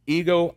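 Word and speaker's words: ego (ἐγώ): Now, we have to understand there's another Greek phrase that's used here, and it's pronounced ego, I, me, okay?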